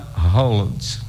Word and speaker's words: Haaland: Então, os dois A do Haaland, como a gente pronuncia aqui, Cristina Pelourinho, lá é dois A, a pronúncia de O, ok? Então, é Haaland.